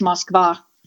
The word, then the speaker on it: Moskva: Either way, that's the English pronunciation. Russian is Moskva.